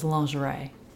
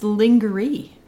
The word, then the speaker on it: lingerie: L-I-N-G-E-R-E. – Almost. I-E at the end. – I-E. – But nothing about that says lingerie. It's lingerie. Lingerie. Wouldn't you say that? – Lingerie. – It's not spelled the way it's pronounced. I know that's true of a lot of words, but lingerie is way out there.